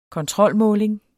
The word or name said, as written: kontrolmåling